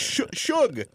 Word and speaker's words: Suge: S-U-G, but it's short for sugar. It's Suge.